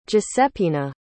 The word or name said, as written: guiseppina